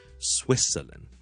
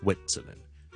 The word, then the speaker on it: Switzerland: 嗱，瑞士呢个名咧，听住 Switzerland。我是读呢 Switzerland，不是 Switzerland，大家听着 Swit，有个 T 音利尖顶住上面门后面那些少少肉的个位 Swit T 音，但我不用 T 出来的 Swit 跟住 Switzerland 就不要读成呢 Switzerland，不是 Switzerland，是 Switzerland，大家练得先慢慢来的 Swit Switzerland，咁例句啦，He's from Switzerland， he's from Switzerland，即系咧佢系来自瑞士嘅。